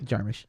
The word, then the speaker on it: Jarmusch: Jarmusch.